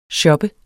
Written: shoppede

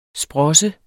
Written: SPROSSEN

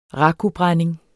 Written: rakubrænding

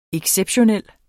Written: exceptionel

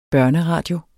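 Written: børneradio

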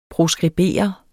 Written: proskribere

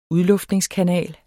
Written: udluftningskanal

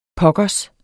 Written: pokkers

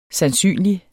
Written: sandsynlig

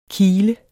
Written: kile

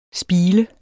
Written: spiler

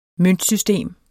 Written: møntsystem

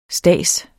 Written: stads

